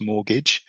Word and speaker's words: mortgage: And in actual fact, when you spell it, it's spelt M-O-R-T and then gidge or gauge or gidge, mortgage, because it's said quite quickly, the second bit. So it's mortgage. But the R is silent in this case.